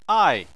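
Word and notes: ai: tone 4

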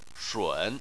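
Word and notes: shun3: tone 3

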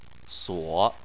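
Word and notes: suo: tone 3

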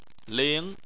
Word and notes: ling: 2

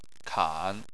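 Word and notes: kan: tone 3

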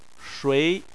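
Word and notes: shui2: tone 2

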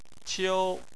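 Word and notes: qiu: tone 1

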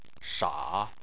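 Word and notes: sha: tone 3

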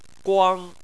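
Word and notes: guang: tone 1

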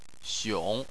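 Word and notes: xiong: xiong3; tone 3

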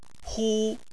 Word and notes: hu: 1

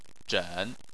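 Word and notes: zhen: tone 3

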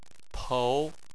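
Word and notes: pou: tone 2

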